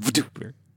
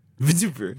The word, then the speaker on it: VTuber: It's pronounced VTuber. – VTuber.